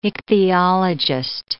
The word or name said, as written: ichthyologist